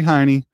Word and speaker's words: Heine: Clock Boy, according to Debrie Heine, that's how you pronounce her name, H-E-I-N-E.